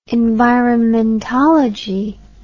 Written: environmentology

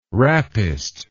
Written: rappist

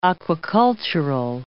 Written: aquacultural

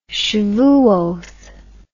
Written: shevuoth

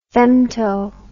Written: femto

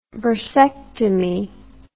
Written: bursectomy

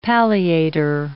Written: palliator

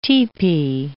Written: tepee

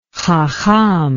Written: hakham

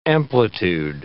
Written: amplitude

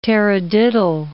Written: taradiddle

